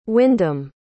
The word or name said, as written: Windham